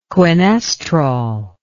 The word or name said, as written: quinestrol